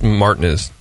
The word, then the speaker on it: Martinez: It's pronounced Martinez.